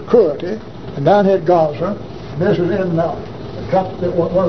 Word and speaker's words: Gaza: All right, thus saith Jehovah, for three transgressions of Gaza, I used to pronounce Gaza, but I hear all the television, they always pronounce it Gaza. So I guess the Hebrews, the Jews pronounce it Gaza. So if I sound like I'm trying to put on the calling it Gaza, all I'm trying to do is pronounce it like they do today.